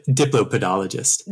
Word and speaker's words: diplopodologist: Decapodologist? – Diplopodologist. – Diplopodologist? I said it wrong again. It's diplopodologist.